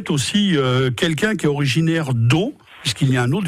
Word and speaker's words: Dot: Mais oui, aussi, on peut penser à une variante, parce que il y a une variante où on prononce pas la lettre terminale qui est Do, D-O-T, donc une dot.